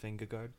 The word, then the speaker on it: Vingegaard: He won on Monday, And then I'm going to go for Jonas Vandegoff, if I pronounce his last name correctly, for Jumbo. – Oh, okay. Oh, really? – Yeah, Jonas Vingegaard.